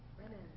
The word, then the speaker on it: renin: Say it like you mean it. – Renin. – Yes, renin or renin.